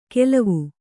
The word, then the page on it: ಗೆಲ್ವು: kannada